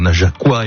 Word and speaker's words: Najacois: Depuis 20h, on s'est pas mal baladé, même si on a offert un joli coup de projecteur aux Najacois et aux Najacoises. Bien comme ça qu'on prononce. – Tout à fait.